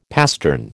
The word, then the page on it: pastern: American English